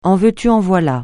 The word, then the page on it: en veux-tu en voilà: français